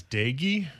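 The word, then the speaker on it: Doege: Yesterday, Jarrett Doig, I'm not even sure if I'm saying his name right. – I think it's Daigie.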